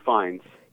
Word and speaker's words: Fiennes: It was uh, how do you say it? Ralph Finn Finn Finnes. – Rafe. Oh, sorry. Yeah, Rafe Fiennes.